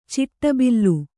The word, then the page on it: ಚಿಟ್ಟಬಿಲ್ಲು: ciṭṭa billu